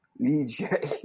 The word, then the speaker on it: Ligier: Not legionnaires. Ligier.